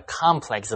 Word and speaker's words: complex: Well, we better stop here before I have a complex about studying English. – Okay. Have fun studying. – 来看 complex 这个字，首先注意它的发音。当形容词要读 complex，当名词读 complex。